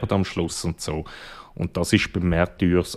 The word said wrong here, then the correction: Martyrs: äh, Martyr, jetzt habe ich schon wieder falsch gesagt, Martyr ist das natürlich, also beim Original, es gibt kein Happy End im engeren Sinn.